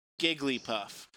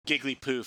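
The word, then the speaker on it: Jigglypuff: I'm sorry. We're going to have to get you to pronounce that name correctly. – Gigglypuff, sorry. – There we go. – That's better.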